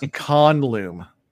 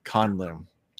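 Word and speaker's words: Canluum: It's pronounced Canlum. Canlum. – There's an N in there? – Pecan. – Con. C-A-H-N. Loom. L-O-O-M. – Canlum. – It's that guttural. You got to get the guttural sound.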